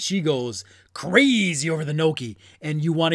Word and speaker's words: gnocchi: Gnocchi? Ganache? Gnocchi? However you pronounce that word, I'm kidding. It's gnocchi. It's gnocchi.